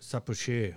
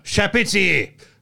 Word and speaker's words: Chapoutier: Chapoutier. Yeah. Okay, wrong pronunciation. Chapoutier.